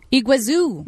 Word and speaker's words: Iguazu: Iguazu Falls. – Iguazu.